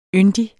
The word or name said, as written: yndig